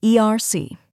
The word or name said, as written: ERC